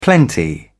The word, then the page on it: plenty: British English